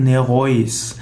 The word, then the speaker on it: Nereus: Nereus. Nereus ist ein Meeresgott in der griechischen Mythologie. Nereus wäre vielleicht der korrektere Ausdruck, also Nereus wird es geschrieben, aber höchstwahrscheinlich wurde ausgesprochen Nereus.